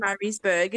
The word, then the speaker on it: Murraysburg: I just need to clarify for everyone listening, because I'm sure there are a whole bunch of people going, no, no, you are pronouncing Murraysburg incorrect. We do live in Murraysburg. It is a tiny little town, just like 90 kilometers from Crofrenet. So it does exist, named after Dr. Andrew Murray. So we do exist, and Zoe is not mispronouncing the town's name.